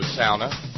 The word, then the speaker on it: sauna: It is a downright balmy 86 degrees here in the Horn Studio sauna. Thank you, Janie. I've since learned in my travels to the far north that really is how they pronounce that word up there.